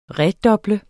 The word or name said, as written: REDUBLET